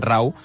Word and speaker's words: Rauw: Mira, li acabo de demanar l'Anna Ballonesta, que és molt fan d'alguns d'aquests cantants, com es pronunciava exactament, Rauw, molt bé. No, perdona, Rauw, que escolta'm, no?